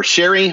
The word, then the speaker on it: sherry: So she crab soup is you take some crab, you take cherry or sherry. I always pronounce that wrong.